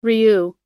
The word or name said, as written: ryu